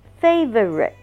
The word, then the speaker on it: favorite: 下一个字呢, favorite, favorite, favorite, favorite, favorite, 它一共发音有三段, 高音在第一段, 把它放慢读, favorite, 读快一点, favorite, favorite, 建议大家先读慢, favorite, favorite, 每一个音都要发出来, 然后觉得自己发音很准了之后才快一点, favorite, favorite, jeans, J-E-A-N-S, jeans, 牛仔裤, 一定是 jeans, 一定是有S, 因为外国人觉得你有两条腿, 所以牛仔裤必须要有一个S.